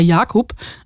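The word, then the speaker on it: Jakub: Also er spielt ähm, im englischen Original wird es Jacob ausgesprochen, aber wahrscheinlich eher Jakob, also tschechisch.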